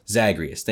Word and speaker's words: Zagreus: Zagreus.